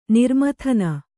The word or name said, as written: ನಿರ್ಮಥನ